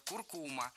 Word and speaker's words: куркума: А в словарях, рекомендованных Министерством просвещения, норма куркума без вариантов. Так что следует придерживаться пока такого ударения – куркума.